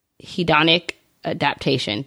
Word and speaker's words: hedonic adaptation: is the hedonic adaptation.